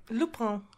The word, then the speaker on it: Lupin: But it's French, so you have to pronounce it silly. – Lupin.